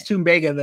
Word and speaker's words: tumbaga: He said Tumbaga and she says Tumbaga. Like, so they had like a silent battle of how to say it. It's Tumbaga then, because I'm going with Dr. Brousseau.